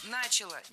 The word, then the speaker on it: начала: И британские мандаты, и то, как Британия плавно начала отходить от своего статуса глобальной империи. – Начала, неверно. Опять же, переносим ударение на окончание, начала, и живем спокойно.